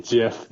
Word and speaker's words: GIF: It's just this animated GIF. – He just pronounced it GIF. – Yeah. – Which is correct.